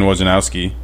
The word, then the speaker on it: Wojnarowski: Adrian Wojnarowski, I think that's how you say his name.